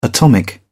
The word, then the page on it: atomic: British English